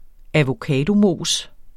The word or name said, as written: avocadomos